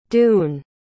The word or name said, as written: doune